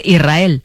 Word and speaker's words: Israel: Y la S con la R es difícil de pronunciar. La gente dice Israel, sin que suene la S.